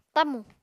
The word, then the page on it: tamù: apatani